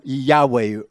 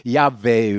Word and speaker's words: Yahweh: Mais euh, on prononce en général comme Yahweh ou Yahvé ou Yova ou quelque chose comme cela.